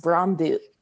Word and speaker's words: Brøndby: All my Danish has gone out the window, but it's pronounced Brøndby. The Y, yeah, the Y's are like U's over there, which I kind of, yeah, it was difficult.